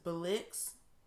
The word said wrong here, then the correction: Blexit: I don't know. – Just the group org saying they want black people to lead the Democratic Party. – Oh, Blexit. – Blexit.